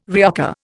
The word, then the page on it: reoccur: Indian